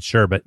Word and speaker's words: sherbet: Like, I thought that's how I remembered it, but it's the rainbow sherbet because there's only one R in sherbet.